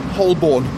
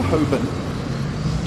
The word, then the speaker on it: Holborn: And so Hoban, many people pronounce as Holborn, but is the jury's still out on that. Is it Holborn? Is it Holborn? Is it Hoborn or Hoban? So I prefer Hoban.